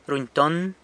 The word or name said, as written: runyton